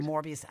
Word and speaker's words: Morbius: And the first is a movie called, is it Morbius? Am I pronouncing that right? – You are indeed. – Morbius.